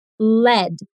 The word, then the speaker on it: lead: Bora então para a segunda pronúncia agora, que é led. Repete, led. – Lead significa grafite ou chumbo.